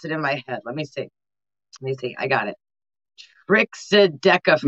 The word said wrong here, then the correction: Triskaidekaphobia: No, you already screwed it up. – Triskaidekaphobia.